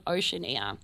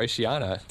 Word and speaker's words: Oceania: And now we're going into the Smashing Pumpkins' Pale Horse, obviously from the album Oceania. – Or Oceana, depending on your pronunciation.